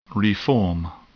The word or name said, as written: reform